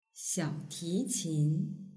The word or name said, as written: xiaotiqin